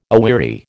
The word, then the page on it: aweary: American English